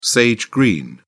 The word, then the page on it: sage-green: British English